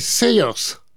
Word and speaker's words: Saoirse: 1h58 pour ce Outrun avec, alors là, je ne sais pas comment on prononce, Saoirse Ronan, mais je crois qu'on dit Saoirse Ronan. – Ah non, bon. j'ai cherché la phonétique. – Oui, oui. – Et apparemment, c'est Sayers.